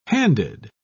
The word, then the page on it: handed: British English